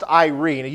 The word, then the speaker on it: eirene: This word peace here is pronounced Irene. You can see how it's spelled E-I-R-E-N-E.